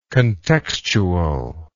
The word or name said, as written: contextually